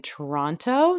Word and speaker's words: Toronto: I'm pronouncing it with the second T, as we were kind of discussing before we started recording this episode. If you're actually from Toronto, then you don't pronounce the second T, but my mom was an English teacher, so I still pronounce it with the second T, despite living here.